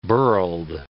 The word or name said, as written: burled